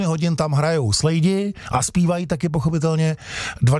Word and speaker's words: Slade: Jak se teda píše Slade foneticky? Slade, krade nebo dlabe? – No, slade. – Slade, přesně tak. Hele, a je to i podobný, viď? Slade a čte se to Slade.